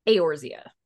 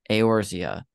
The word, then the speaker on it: Eorzea: Eorzea. – Eorzea.